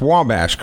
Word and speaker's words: Wabash: Now, Wabash, it is pronounced Wabash, correct? – Yes, sir.